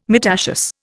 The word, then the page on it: myrtaceous: Indian